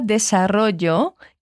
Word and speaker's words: desarrollo: Presta atenção em como ela fala desarrollo, que é desenvolvimento. Desarrollo. Esse show é dos dois L's da palavra. Essa é uma das pronúncias possíveis, ok?